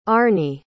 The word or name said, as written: Arnie